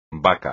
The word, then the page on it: baca: espagnol